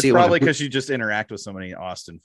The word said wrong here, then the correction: Aston: Aston. I'm sorry. I mispronounced it.